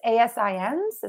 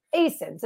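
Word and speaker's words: ASIN: And did I said it wrong. We talked about it before the show. It's ASINs. Is that right? – ASINs.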